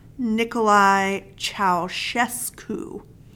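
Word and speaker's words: Nicolae Ceaușescu: And the dictator there, I also looked up how to say his name, Nicolae Ceausescu.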